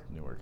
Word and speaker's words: Newark: It's Newark. – I cannot concur. – No? – I say Newark. – Newark? – Newark. It's a two-syllable word.